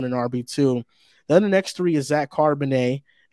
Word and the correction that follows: Charbonnet: and I hope I'm saying that right. Am I saying that right? – No, it's just Charbonnet. Sounds like a nice fine wine.